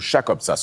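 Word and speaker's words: Chakobsa: There's a language, the Fremen language, Chukotka. Am I saying that correctly? – Absolutely. Chukotka. Yeah.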